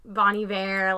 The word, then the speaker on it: Bon Iver: Yeah, they give me that, like, she likes that deep Bon Iver, like, voice.